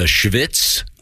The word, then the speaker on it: Schvitz: I'll spell it first. S-C-H-V-I-T-Z. The Schwitz.